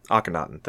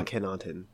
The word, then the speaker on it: Akhenaten: Akhenaten, thank you. – Oh, yeah. – Akhenaten.